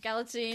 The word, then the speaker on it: Galitzine: Nicholas Galitzine, is that how you pronounce it? Galitzine. – Kind of like magazine.